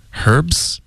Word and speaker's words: herb: 就比如 Herbs。英式的发音会保持这个 H 音，美式的发音就会用法语的发音，因为这是法语、借用过来的一个字，所以就发 herb。 – 你看、 H 音也没有了，对、啊，也没有了，所以就看情况。